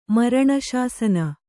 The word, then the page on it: ಮರಣಶಾಸನ: maraṇa śasana